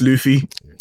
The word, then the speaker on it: Luffy: It's pronounced Luffy.